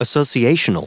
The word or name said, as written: associational